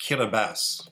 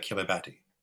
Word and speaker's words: Kiribati: It's pronounced Kiribati, but it looks like Kiribati.